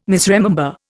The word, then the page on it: misremember: Indian